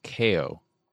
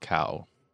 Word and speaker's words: Kao: So it's pronounced Kao. Okay, not Kao. Kao the Kangaroo.